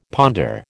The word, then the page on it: ponder: American English